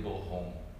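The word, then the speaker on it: Boron: esse Borron com um R só apesar de se pronunciar como Rom, né, mas é um R só. Robert de Borrom.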